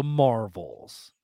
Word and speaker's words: Marvels: No, so after Guardians of the Galaxy Volume 3, we are going to get the Marvels. So with the emphasis on the S, because we have three characters that have connection to the word Marvel.